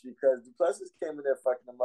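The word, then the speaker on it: Du Plessis: And then the fact that in the second round, you seen Duplessis was, it's Duplessis, the S is silent.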